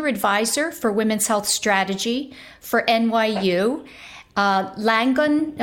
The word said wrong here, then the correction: Langone: am I pronouncing that right? Langon Health? Langone. Yeah. Langone Health,